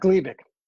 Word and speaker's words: Gleevec: So, am I saying it right? Gleevec, or is that Gleevec? That's right.